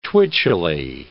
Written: twitchily

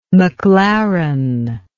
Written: maclaren

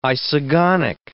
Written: isogonic

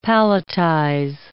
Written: palletize

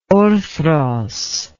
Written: orthros

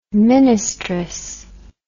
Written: ministress

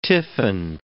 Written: tiffin

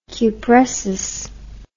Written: cupressus

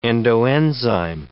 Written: endoenzyme

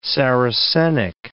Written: Saracenic

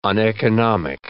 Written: uneconomic

